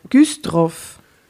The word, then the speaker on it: Güstrow: Güstrow. Wie schreibt man das? G-U-S-T-R-O-W.